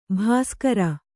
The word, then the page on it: ಭಾಸ್ಕರಿ: bhāskari